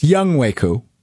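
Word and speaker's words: Younghoe Koo: Young Hoku. – All right. So that is incorrect. It's Young Waku.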